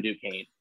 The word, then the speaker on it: Duquesne: it's Duquesne, by the way. We just say Duquesne because that's funny.